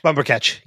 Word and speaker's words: Bumbercatch: Like I can never say his name right. Is it Bumbercatch? – Bumbercatch. – Yep. Okay.